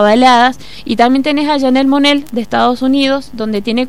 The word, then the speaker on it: Janelle Monáe: Janelle Monáe, que pronunciamos bien su nombre.